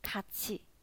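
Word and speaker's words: 卡 气 (같이): 七啊，所以连起来，卡气，卡气，卡气呢，在韩语当中就是一起的意思，一起卡气。然后第三个字呢是卡，卡，卡在韩语当中就是去的意思，所以卡气卡，卡气卡就是表示一起去啊，卡气卡就表示一起去。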